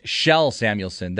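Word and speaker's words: Kjell Samuelsson: Thank you to the texters that are bringing up that it's pronounced Shell Samuelsson. That's Matthias's father, Shell Samuelsson.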